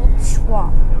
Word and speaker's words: schwa: Schwa. You never really hear the schwa unless it's in Middle English. Schwa, that's how you pronounce it by itself.